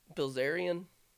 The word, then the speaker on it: Bilzerian: Dave Portnoy of Barstool Sports, Justin Bieber, or Dan Bazarian. How do you say that name? – Bilzerian.